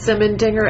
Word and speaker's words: Simendinger: You know me, Jim, Kirsten Downey, D-O-W-N-E-Y. – And I'm Alexis Simendinger, and that's spelled S-I-M-E-N-D-I-N-G-E-R, and I'm part of the writing team also here at the FCIC.